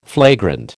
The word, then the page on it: flagrant: American English